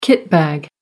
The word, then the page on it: kitbag: American English